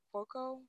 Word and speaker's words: Cuoco: And it stars, how do you say her last name? Cuoco? – Cuoco? Sure.